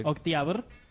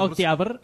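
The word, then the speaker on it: Octiabr (Октябрь): Octiabr. – Ah, sí se pronuncia. – Octiabr.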